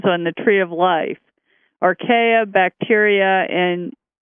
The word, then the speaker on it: Archaea: Let me see if I'm pronouncing it correctly. Archaea, is that right? – Archaea, probably. – Archaea, okay.